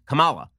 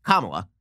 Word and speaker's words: Kamala: I used to pronounce it the way that it kind of looks on paper, which is Kamala, right? It looks like the emphasis should be on the second syllable. But in fact, the emphasis is on the first syllable.